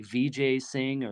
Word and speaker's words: Vijay Singh: I forget entirely what his name is, but his name is like Vijay Singh or Vijay.